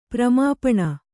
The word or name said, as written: ಪ್ರಮಾಪಣ